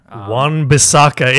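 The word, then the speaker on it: Wan-Bissaka: I thought Juan Bieksa looked phenomenal. He was everywhere. – Juan Bissaka. It continues. We came back from the break, and he still can't pronounce names.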